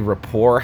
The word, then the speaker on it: rapport: We had like a snappy rapport happening, like, in that instance. – How do you spell rapport? – R-A-P-P-O-R-T. R-E-P-P-O-R-T? – R-A-P-P-O-R-T. – R-A? – Yeah. – There's a silent T? Did you say there was a T at the end of that? – R-A-P-P-O-R-T.